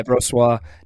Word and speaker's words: Brossoit: Yeah, Brossoit.